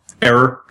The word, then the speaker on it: Eror: Eror. Just like... – Eror? Okay. – Yeah. Just like NS Eror, except it's spelled differently.